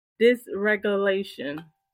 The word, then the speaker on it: dysregulation: Emotional dysregulation.